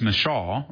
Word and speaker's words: Michal: So sad, because Saul, one of the things you see here with Merab, as well as Michal, or Michael, some people have a varying pronunciation of her name, don't miss the depravity of this man.